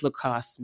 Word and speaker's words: Lacoste: Like, what does Lacoste mean? – Yeah. – And I'm pronouncing it right. Let's start there. – No, you did it.